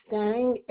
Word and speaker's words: Stang: Stang, S-T-A-N-G, my zip code 15236. – You said S-T-A-N-Z as in zebra? – No, G as in George.